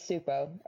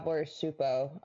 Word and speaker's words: Csupo: Yes, it's Supo. Or, like, Gabor Supo. I think that's how you pronounce it. C-S-U-P-O. Supo.